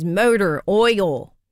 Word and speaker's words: Mowder Oyal: That is Motor Oil. Yes, it is spelled phonetically to have that Baltimore accent. That beautiful Baltimore accent spelled M-O-W-D-E-R-O-Y-A-L.